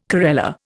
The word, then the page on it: corella: Indian